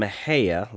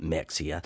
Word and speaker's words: Mexia: You're from Mahia. A lot of people from out of state pronounce it Mexia.